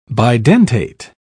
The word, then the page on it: bidentate: British English